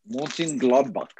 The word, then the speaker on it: Mönchengladbach: My Borussia Mönchengladbach? – Mönchengladbach.